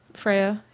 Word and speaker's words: Freya: Oh, is that, did I say it right? – Freya, yeah.